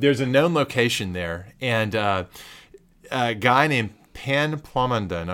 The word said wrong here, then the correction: Pun: I'm not sure if I'm pronouncing the last name. – Pun Plamondon. – Pun. Did I say Pan? – Yeah.